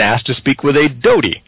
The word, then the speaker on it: Dody: the name Doty, although it's spelled D-O-D-Y, conveniently.